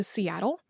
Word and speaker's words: Seattle: And that also is an Americanized pronunciation. Uh, like many non English names, it includes characters and phonemes that don't exist in English. Um, and, an approximation of the actual pronunciation of it is Siat. Uh, and we don't really end words with t in that way. Mm-hmm. Uh, and so it, it sort of gradually became softened to Seattle according to the Suquamish Foundation,